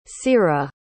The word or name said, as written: cyrah